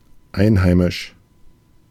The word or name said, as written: aboriginal